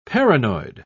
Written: paranoid

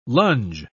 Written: lunge

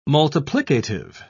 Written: multiplicative

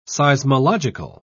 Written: seismological

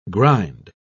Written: grind

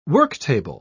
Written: worktable

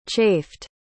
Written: chafed

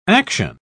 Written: action

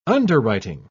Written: underwriting

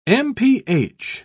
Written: mph

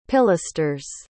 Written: pilasters

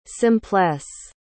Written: simplesse

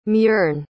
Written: muirne

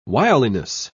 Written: wiliness

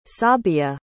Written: sabia

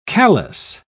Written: callous

